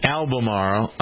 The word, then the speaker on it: Albemarle: Bill is an Albemarle. I always try to pronounce that correctly. I think I did, Bill. Albemarle, North Carolina, right? – You got it. Perfect.